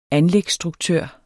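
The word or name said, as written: anlægsstruktør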